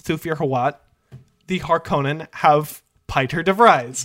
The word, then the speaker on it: Piter: It's spelled P-I-T-E-R, so I have to assume it was actually Peter, but both of the movies have pronounced it as Piter, so I have to assume that Frank Herbert's intention was that his name is Piter DeVries.